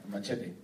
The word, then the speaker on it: Machete: How do you say it? Machete or Machete? – Machete. – Yeah, yeah. – Machete. – Yeah, that's how he says it.